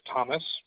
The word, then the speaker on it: Thomas: Yeah, my first name is Thomas. – Okay. – That's spelled T-H-O-M-A-S.